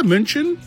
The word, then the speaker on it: München: Yeah, München.